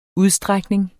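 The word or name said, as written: UDSTRÆKNINGEN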